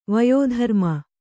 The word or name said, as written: ವಯೋಧರ್ಮ